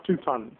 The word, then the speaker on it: touton: And we believe how, I don't know where the name came from, Tauten, T-O-U-T-O-N. It looks like Tauten, but it's Tauten. And it's pronounced Tauten.